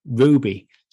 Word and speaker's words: RWBY: And the last one for the year, Justice League Ruby, Superheroes and Huntsman Part 1. That's that crossover thing we talked about, and we weren't quite sure on the pronunciation, RWBY, but I think we settled on Ruby.